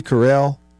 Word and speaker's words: Carell: Beaver, if Michael Scott, played by Steve Carell, did I get his name right? – Yeah.